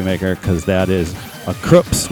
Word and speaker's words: Krupps: And it's pronounced Krups, named after the German dynasty that assisted the Nazis with arms during World War II.